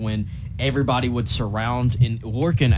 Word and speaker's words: Lorcan: It is Lorcan. So L-O-R-C-A-N and then Quinn. Lorcan Quinn.